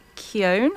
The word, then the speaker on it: Barry Keoghan: I've already mentioned to you off-air, Lorcan, who my favourites are, Barry Keoghan, have I said that right? – Yes.